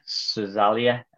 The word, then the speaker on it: Szalai: Attila Szalai, spelt S-Z-A-L-A-I.